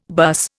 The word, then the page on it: buss: Indian